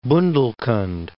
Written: Bundelkhand

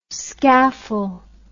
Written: scaffle

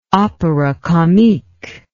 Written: opera comique

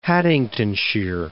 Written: Haddingtonshire

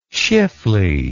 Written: schiffli

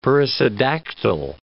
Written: perissodactyl

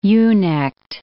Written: ewe-necked

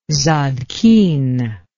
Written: zadkine

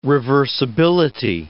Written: reversibility

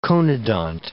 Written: conodont

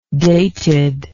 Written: dated

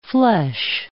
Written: fleche

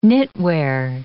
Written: knitwear

